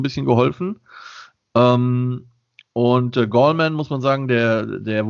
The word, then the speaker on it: Gallman: war der Running Back Wayne Gallman oder Gallman wird er ausgesprochen.